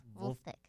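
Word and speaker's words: Vulfpeck: Wolf Well, Wolfpick, but with a V. Wolf.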